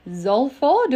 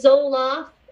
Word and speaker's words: Zolfo: So welcome, Julie Zolfo. Do I say that right? – You did, Zolfo. Some people want to say Zoloft. I'm not the antidepressant. I'm the exact opposite.